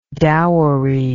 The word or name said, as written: dowery